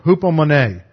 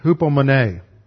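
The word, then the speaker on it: Hupomone (ὑπομονή): Hupomone. H-E-P-O-M-O-N-E. Hupomone.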